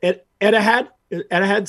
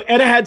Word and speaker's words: Etihad: I'm probably saying this wrong. Is it Etihad Stadium? Am I saying that correct? – I think so.